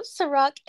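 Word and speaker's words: Cîroc: Is it Ciroc? – Yep, Ciroc.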